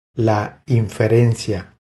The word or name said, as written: La inferencia